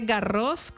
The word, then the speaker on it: Garros: Ahí va la primera cascarita, se dice, bueno, se dice Garros con S al final porque es nombre propio. Sí, en francés la S no se pronuncia, pero esta sí.